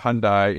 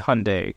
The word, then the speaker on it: Hyundai: Like, why not buy a Hyundai instead of a Lexus? Like, if you can afford a Lexus, you'll buy one, right? – It's Hyundai. – Hyundai, oh, sorry.